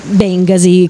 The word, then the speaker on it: Bengasi: Stiamo parlando di Piazza Bengasi. Bengasi. – L'ho fatto dire a voi perché, da torinesi la piazza Bengasi viene pronunciato da sempre Bengasi, con l'accento sulla E, sulla prima E.